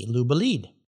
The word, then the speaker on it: Le Bolide: And I'll remind our listeners, it's spelled L-E-B-O-L-I-D-E, Labolite.